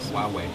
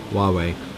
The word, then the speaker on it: Huawei: Oder wie spricht man es aus? – Huawei. – Huawei.